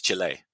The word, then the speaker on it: Chile: I say Chile because that's the correct pronunciation. Sometimes I hear you lot in England say Chile. It's Chile.